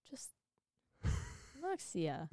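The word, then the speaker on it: Muxía: Muxia. What would you say there? – Just Muxia. – Muxia.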